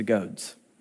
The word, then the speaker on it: goads: It is hard for you to kick against the goads. Now, I didn't mispronounce that word. It's not goats. It is goads.